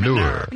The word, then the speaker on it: manure: Manure. M-U-H-N-O-O-R is the pronunciation guide.